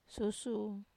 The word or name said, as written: susu